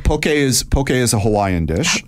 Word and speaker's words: poke: It's spelled P-O-K-E, but it's spelled like okay. Sorry, it sounds like okay, poke. – Right. Yeah. – Okay, that's how you got to say it, not pokey.